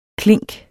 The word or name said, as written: klink